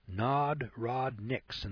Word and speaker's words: Narodniks: And these agitators are called Narodukes, pronounced Narodniks, Narodniks in the Soviet Union.